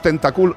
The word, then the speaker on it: tentáculos: Ahí lo Estos animales utilizan estos tentáculos, tentáculos, mirad también lo importante que es el acento en su sitio, para atrapar a sus presas.